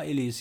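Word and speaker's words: Illies: Illis, wird so ausgesprochen? – Ja, Illis, ja.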